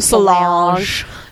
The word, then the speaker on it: Solange: Solange.